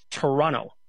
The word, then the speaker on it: Toronto: Do you know that the second T in Toronto is actually not pronounced? It's Toronto.